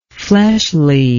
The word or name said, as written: fleshliness